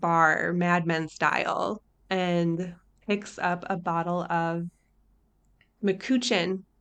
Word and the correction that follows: McCutcheon: Did I say that right? – I say McCutcheon. – McCutcheon. But... That's... Mm-hmm. McCoochin sounds weird. McCutcheon. Okay.